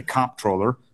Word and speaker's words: comptroller: I happen to say comptroller. I hit it hard on the first syllable, and some people say controller.